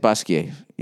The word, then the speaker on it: Basquiat: All right, uh, Basquiat slash Basquiat. Is that how you say it? Basquiat. Yeah, I've learned that with my French studies. You don't pronounce the T unless there's an E after it.